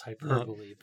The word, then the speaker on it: hyperbole: Yeah, the word you, well, the actual pronunciation is hyperbole, but I've made that error myself in the past.